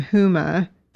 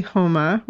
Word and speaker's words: Huma: For those of you who don't know, and many people pronounce her name Huma, but it's actually Homa, which I've learned by listening to her say it.